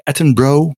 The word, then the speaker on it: Attenborough: ik, ben ook gecorrigeerd op de uitspraak. Attenborough moet het zijn.